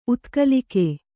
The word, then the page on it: ಉತ್ಕಲಿಕೆ: utkalike